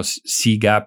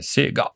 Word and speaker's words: Sega: No, io dico Siga per questioni di evitare di dire una parola ambigua in italiano. All'estero si usa la pronuncia con l'impostazione giapponese, Sega.